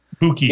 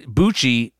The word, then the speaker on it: buchi: I believe it's called cinque buche. Buchi. Yep. – Cinque. And buchi. So let's see, how to pronounce buchi.